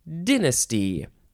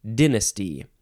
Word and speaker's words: dynasty: A dynasty. You can say dynasty, dynasty, dynasty. And the Brits say dynasty. They don't like to say die, they like to say di, dynasty.